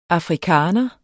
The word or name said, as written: afrikaaner